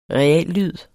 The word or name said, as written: REALLYD